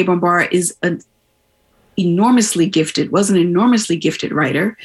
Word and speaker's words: Bambara: B-A-M. I wanted to spell it. – B-A-M-B-A-R-A. – Yeah. – Bambara.